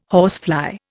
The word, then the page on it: horsefly: Indian